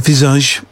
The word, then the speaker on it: Visage: That's how you say it, Visage, apparently.